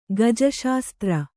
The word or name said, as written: ಗಜಶಾಸ್ತ್ರ